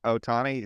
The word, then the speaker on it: Ohtani: Yeah, he's Army's Shota Ohtani. Did I say that right? – Yeah.